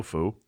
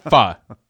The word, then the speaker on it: pho: No foo. No. No foe. – It's actually pronounced pho.